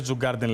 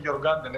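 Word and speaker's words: Djurgården: Λέει, μην τη λες Τζουγκάρντεν, λέει, αν θες. Στα σουηδικά λέει το D δεν διαβάζεται. Νομίζω λέγεται Hugh Gordons. Αν το λέω, θα γελάνε μαζί μου στην Τρίπολη. Αλμύρα, πες το σύρε, πώς εκεί. – Και με διορθώσανε, είναι Γιωργκάρντεν, έτσι το λένε εδώ πέρα.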